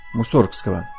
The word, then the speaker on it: Мусоргского: По некоторым сведениям, правильное ударение фамилии композитора ставится на второй слог – Мусоргского.